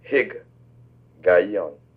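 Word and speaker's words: Higgaion: Now we come into a beautiful Jewish word, it's called, or Hebrew word, Hig Gayon.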